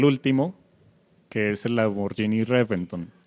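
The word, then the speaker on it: Reventón: Según los desarrolladores, según los que presentan el programita este de Top Gear en la BBC de Londres, no se pronuncia, se escribe con V, pero no se pronuncia con V, o sea, no se pronuncia Reventon, se pronuncia Reventon.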